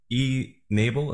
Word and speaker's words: e-NABLE: And as I think about the E-Nable, is that how it's said? – Yeah.